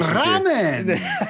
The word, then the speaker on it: ramen: But like the birria ramen or ramen. I can't like, I'm like screwing up the pronunciation. – Ramen.